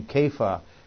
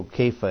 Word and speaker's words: Kepha: So we call him Simon Peter, or he's called also Kepha, as I said. And in your Bible, sometimes it's transliterated C-E-P-H-A-S, so it comes out Cephas, which is quite far removed from the Aramaic pronunciation.